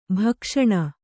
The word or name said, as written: ಭಕ್ಷಣೆ